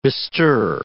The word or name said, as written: bestir